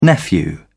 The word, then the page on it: nephew: British English